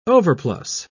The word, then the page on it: overplus: British English